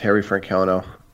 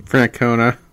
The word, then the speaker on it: Francona: They move on from Terry Francona. Why am I saying his name wrong? – Francona.